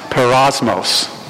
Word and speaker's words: peirasmos: In the Greek, there's a word, and it's spelled P-E-I-R-A-S-M-O-S. That's how it transliterates out of the Greek. It's pronounced perosmos.